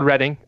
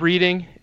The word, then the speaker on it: Reading: It's called Reading, also known as Reading if you are from Ohio.